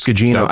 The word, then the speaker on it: Gugino: I always go Gugino. – It's Gugino. I think it is Gugino.